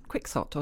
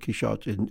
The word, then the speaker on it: Quixote: But actually, that CH, the sh pronunciation, is very general. In French, he's called Quixote. In Germany, it's pronounced Quixote. In Italian, it's pronounced Quixote. And even in Spanish, in the Spanish of the time of Cervantes, the X would have been pronounced as a sh, not as a h. You know, so Cervantes himself would probably have said Quixote.